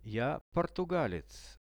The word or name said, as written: ja portugalec